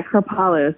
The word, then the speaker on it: Kripalu: Is it Kripalu? – Oh, yes. Yeah, Kripalu.